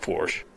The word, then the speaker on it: Porsche: It's pronounced Porsche.